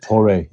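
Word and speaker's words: Torei: Now, do you say it's Tore? Is that how you say it? – Tore, yeah, Tore.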